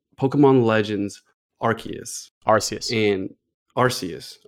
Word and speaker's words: Arceus: but well, in terms of English spelling, it should be pronounced Arceus.